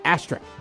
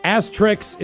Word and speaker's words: asterisk: The next one, I'll say asterisk. – Asterix is incorrect, but I do like asterix, depending on who does them. The correct pronunciation is asterisk.